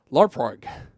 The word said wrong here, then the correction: Laphroaig: Laphroaig. Laphroaig. – Yeah. – Oh, that's Laphroaig.